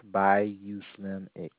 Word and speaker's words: BuyUSlimX: By US Limex? – It's pronounced Bayou Slim X.